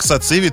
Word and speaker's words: сациви: Итак, сразу могу сказать о том, что сегодня будет непросто, потому что как сациви, так и многие еще блюда именно грузинской кухни, они похожи. – Угу. – И многие могут перепутать. Поэтому я хочу сразу сказать, что ударение идет на сациви.